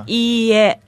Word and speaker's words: Iie: You kind of have to hold the first E, right? Is that correct? – Yeah. Pronounce it longer, like yeah. – Iie, like that.